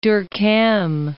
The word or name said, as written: Durkheim